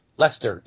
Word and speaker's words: Leicester: Leicester in England.